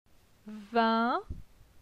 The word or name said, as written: Vin